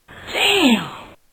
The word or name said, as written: Damn